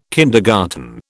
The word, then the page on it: kindergarten: British English